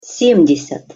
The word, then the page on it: sem-desjat: Russian